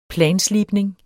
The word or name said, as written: planslibning